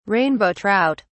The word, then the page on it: rainbow trout: British English